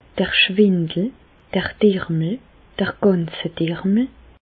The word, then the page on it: d'r Schwindel, d'r Dermel, d'r Gänsedermel: Bas Rhin; Reichshoffen